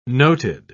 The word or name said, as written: noted